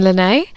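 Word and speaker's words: Lenae: Also, I realised last week I played Raven Lanay, but I kept saying Raven Lay, and I've played her a couple times in the last few weeks, and it's like been haunting me.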